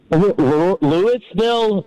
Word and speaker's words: Louisville: Louisville? Yeah. It really doesn't matter, man. If you're from here, though, you pretty much drop the I and the S part of it, though, in the Louis, so it's like Louisville.